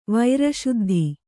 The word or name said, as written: ವೈರಶುದ್ಧಿ